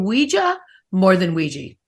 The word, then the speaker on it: Ouija: Phonetically, on the back of some boxes, originally, early 1900s, they actually told you how to pronounce it. They said Ouija. Definitely was a Ouija. And so that's how it was meant to be pronounced.